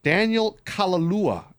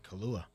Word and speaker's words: Kaluuya: Daniel Kaluuya, I think is how you say it. – He's a Brit. – Sounds like Kaluuya.